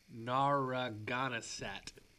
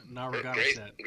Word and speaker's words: Narragansett: Narragansett. – Nicely done. – You like that? Narragansett.